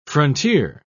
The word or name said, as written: frontier